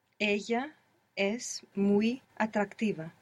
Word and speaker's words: muy: The word for very is muy, and it's spelled M-U-Y. The Y, you can imagine it like an I. Muy.